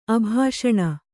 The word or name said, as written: ಅಭಾಷಣ